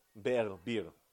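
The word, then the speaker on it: Berr: Bir. It's spelled B-E-E-R, but he's a French Jew.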